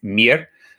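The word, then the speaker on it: mirë: The word for good is mir. It's spelled like the English word Maya, but pronounced mir.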